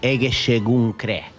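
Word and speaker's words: Egészségünkre: Is it Egeshegedre? – Egeshegedre. Actually, we're going to say Egeshegunkre.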